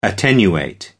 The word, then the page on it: attenuate: American English